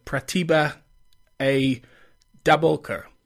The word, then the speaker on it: Pratibha A. Dabolkar: Hess and Pratibha A. Dabolkar.